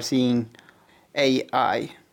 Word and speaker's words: Seeing AI: Den här appen, den heter Seeing AI, eller hur man uttalar det med engelska uttal, Seeing AI.